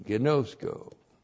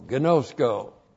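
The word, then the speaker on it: ginosko: Gnosko. G-I-N long O-S-K long O. Gnosko.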